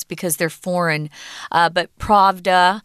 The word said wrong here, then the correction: Prada: Um, oh no, is it Prada? Prada.